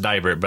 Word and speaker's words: Deibert: I took 3 years of German in high school, and when I saw your last name, I was like, I'm pretty sure that's pronounced Deibert, but I'm going to double check and make sure.